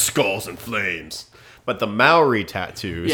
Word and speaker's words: Maori: It's like Maury, it's like, what's it, the Maury, like, tattoos? Is that, am I saying that right? – Oh, like. – Yeah, in, like, the Polynesia, or, like, the. – Yeah, Maori. – Is it Maori, thank you.